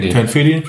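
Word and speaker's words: Ten FIDY: Das Barrel-Aged Tenfidi oder Tenfidi? – Tenfidi, schreibt man es aus, genau, von Oscar Blues Brewery.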